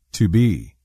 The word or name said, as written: To be